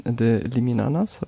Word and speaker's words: The Liminanas: nämlich Russian Roulette von The Liminanas. Habe ich es richtig ausgesprochen? Ja, okay. ja, viel Spaß damit.